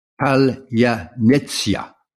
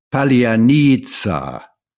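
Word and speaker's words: palyanetsya (паляниця): Now, Ukrainians, uh, including those who mainly speak Russian, pronounce that palyanetsya, while Russians mangle the Ukrainian soft oo sounds like the word English word murmur. The Russians don't have a letter with the Ukrainian ts sound and pronounce the Ukrainian ya as ah. So they this comes out as palianitsa.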